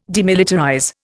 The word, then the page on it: demilitarize: Indian